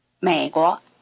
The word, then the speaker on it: 美国: Notice that 国 takes the neutral tone in a country name. Listen to America. – 美国, 美国, 美国.